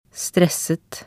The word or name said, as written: stresset